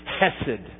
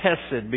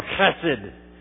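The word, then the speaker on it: chesed (חֶסֶד): And actually, the word is pronounced chesed, because the H is kind of a sound. Although we don't do things like that, and you think, well, he's clearing his throat. It's hesed or chesed.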